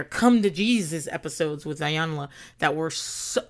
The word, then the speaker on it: Iyanla: I'm, I'm never saying her name right. I think her name is Iyanla. Iyanla. But I think we've all gotten away with saying Ayana but that's not her name.